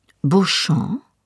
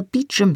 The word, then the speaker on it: Beauchamp: Er hatte den Namen sogar korrekt auf Französisch ausgesprochen, obwohl ich selbst die übliche englische Aussprache Beecham benutzt hatte.